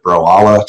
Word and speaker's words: Broala: They called it Broala to rhyme with koala.